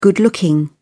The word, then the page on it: good-looking: British English